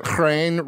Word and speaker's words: chrein: I mean, I think that there is a reason that chrein rhymes with pain.